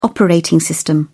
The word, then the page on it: operating system: British English